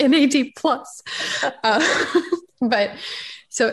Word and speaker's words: NAD: I've even heard it, the way you say it is NAD, the plus is silent.